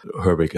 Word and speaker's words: Herbig: My name is Tim Herbig. I'm from Germany, so my name is typically pronounced a bit different, but I'm making it more easy for all the English speakers out there. Herbig, it's fine.